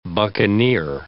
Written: buccaneer

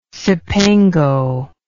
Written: Cipango

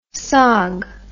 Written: sog

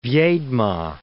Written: Viedma

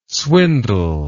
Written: swindle

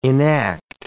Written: enact

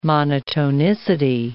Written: monotonicity

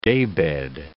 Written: daybed

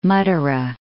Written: Mathura

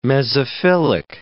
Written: mesophyllic

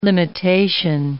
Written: limitation

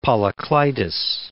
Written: Polycleitus

